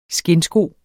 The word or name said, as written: skindsko